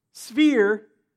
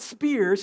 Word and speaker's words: sphere: "And gave us joint seating with him in the heavenly sphere, not spear, sphere."